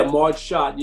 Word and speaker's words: Schott: And the renaming of Marge Schott, is that how you pronounce her name? – Yeah, Marge Schott.